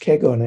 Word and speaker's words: Kegon: But I was starting to say that this sutra was the basis of an important school of Buddhism, the Huayan school in China. It's pronounced Kegon in Japanese.